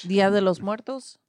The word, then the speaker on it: Día de los Muertos: Día de los Muertos.